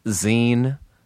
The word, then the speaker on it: zine: It is called It's Pronounced Zine, spelled Z-I-N-E. It's just a dumb joke, but the word is zine.